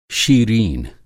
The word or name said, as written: शीरीन